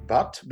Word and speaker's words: Bhatt: My name is Mukesh Bhatt, B-H-A-T-T.